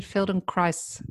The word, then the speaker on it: Feldenkrais: And just because the name's so unusual, what is, what is the background or where did Feldenkrais yeah, I'm saying it correctly.